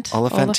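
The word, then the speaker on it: Olyphant: Oliphant.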